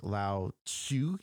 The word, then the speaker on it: Lao Tzu: Like Lao Tzu, who is a Chinese philosopher from like centuries ago, like a long time ago, his name is spelt like Lao Tzu or whatever, you know what it's not, there's a T in there which isn't pronounced.